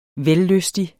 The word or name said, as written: vellystig